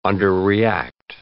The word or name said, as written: underreact